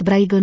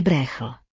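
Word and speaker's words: Bruegel: Různá forma psaní jmen je matoucí, důležité ovšem je, že výslovnost Braigl není správná, jméno se čte jako Brechl či Brechl.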